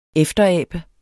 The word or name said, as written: EFTERABEDES